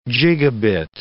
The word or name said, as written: gigabit